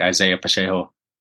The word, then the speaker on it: Pacheco: I think Isaiah Pacheco has a glorious, glorious game against the Chargers.